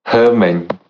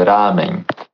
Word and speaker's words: ramen: É, o, a Amanda fala ramen, né? Só que é ramen.